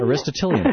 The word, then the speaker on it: Aristotelian: Yeah, there's a real difference in our culture, or in the experience of growing up in our culture, between the Aristotelian, if I'm pronouncing it right. – Aristotelian? – Aristotelian. Thank you very much.